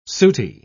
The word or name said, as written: sooty